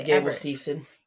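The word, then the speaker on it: Gable Steveson: His last big match was against Gabe Stevelston. Yeah, I can't say his name correctly. – Gabe Stevenson.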